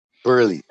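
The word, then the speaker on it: Buehler: And Walker Buehrle, how do you say his last name? – Yeah. Burley. – And Burley starting. And right now it seems like Bueller.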